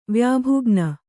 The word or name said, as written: ವ್ಯಾಭುಗ್ನ